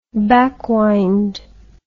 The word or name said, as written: backwind